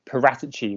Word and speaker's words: Paratici: In terms of the transfers, it's interesting because this new managing director of football, Fabio Paratici, or Paratici, which I heard is apparently the correct pronunciation.